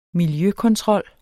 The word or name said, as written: miljøkontrol